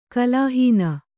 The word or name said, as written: ಕಲಾಹೀನೆ